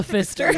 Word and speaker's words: Pfister: Because it's called the Fister. Yeah. – it's spelled P F I S T. – Okay, so not a fist.